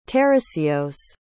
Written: tarasios

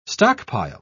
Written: stockpile

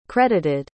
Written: credited

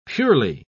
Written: purely

